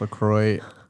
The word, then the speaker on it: LaCroix: I would say it's pronounced LaCroix I'm almost 100% sure, like 99.9% sure. If you go to the LaCroix website, it says it rhymes with enjoy.